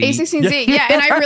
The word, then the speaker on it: a16z: A lot of people do say A16, but it's A16Z. – Yeah. – So, but you were doing it A16Z and Z. You added an and Z.